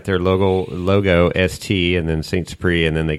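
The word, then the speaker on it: St. Supéry: Spell that, because that doesn't spell the way it sounds like. – It doesn't? So ST and then SUPERY. – That's right. – Saint Supreme, Napa Valley.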